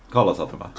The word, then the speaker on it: Kalasatama: Kalasatama. – Yeah, you only emphasize the first syllable of every word without any exception.